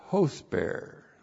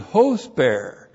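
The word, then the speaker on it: hosper (ὥσπερ): And the comparison is with the Greek word, Koine Greek word, hosper. H long O S P E R. So it's, first of all, Allah, not but now then, strengthening is strengthening hosper.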